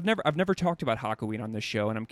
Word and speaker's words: Hakuin: there's one person that really drew my attention, and correct me if I say this incorrectly, but it's at Hakuin. Hakuin. – Yes. Yeah.